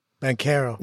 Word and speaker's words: Banchero: And then that puts you in the discussion with Banchero. Is that how you pronounce it? – Banchero.